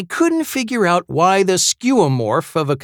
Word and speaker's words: skeuomorph: It's spelled S-K-E-U-O-M-O-R-P-H.